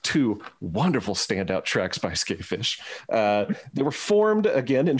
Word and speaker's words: Skafish: It looks like it's ska fish, and it's not a ska band, but it's pronounced Skayfish.